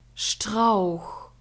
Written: strauch

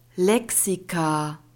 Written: lexika

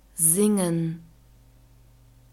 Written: singen

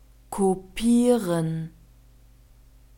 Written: kopieren

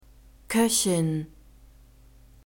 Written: koechin

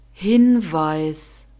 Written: hinweis

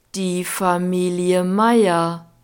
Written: die familie meier

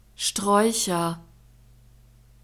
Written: straeucher

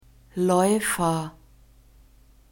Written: laeufer

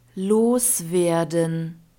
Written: loswerden

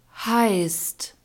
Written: heisst

